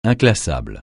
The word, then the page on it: inclassable: francais